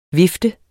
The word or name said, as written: vifte